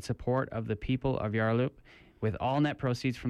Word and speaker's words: Yarloop: But due to bushfire in Yarloop, which I don't know where that is.